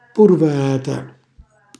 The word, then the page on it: purvarata: calabrese